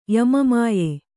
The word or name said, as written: ಯಮಮಾಯೆ